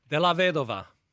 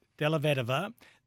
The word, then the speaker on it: Dellavedova: Dellavedova. – Dellavedova.